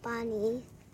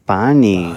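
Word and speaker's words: Pani (पानी): Pani. – Pani.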